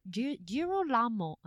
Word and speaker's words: Girolamo: Girolamo.